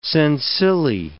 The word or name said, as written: sensillae